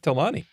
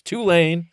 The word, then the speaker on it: Tulane: Now we have a guy named Michael Pratt from Tulane. – Tulane? – Ah, I went out on a limb on that one thinking, you know what? I think it's Tulane, but it's not. It's Tulane.